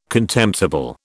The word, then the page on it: contemptible: British English